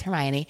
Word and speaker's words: Hermione: So now we all know that it's Hermione.